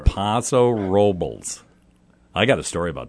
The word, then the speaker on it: Paso Robles: And then I've got a beautiful Zinfandel out of Paso Robles. – No, you didn't say it right. – What's that? – It's Paso Robles is the way they say it. – Paso Robles.